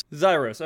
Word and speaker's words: Xyros: I'm going to go clockwise and start with Zeros, right? Is it Zeros? Am I saying that right? Or Zyros? – Zyros. – Zyros, okay.